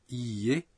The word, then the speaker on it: iie: Quand vous dites iie, vous prononcez le i en le prolongeant d'un temps.